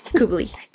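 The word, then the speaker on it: Kubalík: Kubalik it has been a great, is that how you pronounce that? Kubalek. Kubalek. Kubalek. Kubalek has been a fantastic acquisition.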